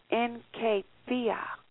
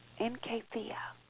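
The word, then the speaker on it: enkephia (ἐγκράτεια): Now, the Greek word for self-control is enkephia, enkephia.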